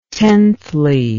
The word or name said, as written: tenthly